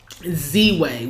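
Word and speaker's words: Ziwe: We started watching Z-Way, which is a show on Showtime.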